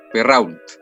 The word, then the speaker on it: Perrault: Mira, aprendí algo nuevo al tiro. Charles Perra. – Perrault. ah, ya. Así parece que se pronuncia.